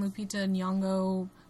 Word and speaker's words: Lupita Nyong'o: Scarlett Johansson, Lupita Nyong'o. Is that how you say her name? – Yeah.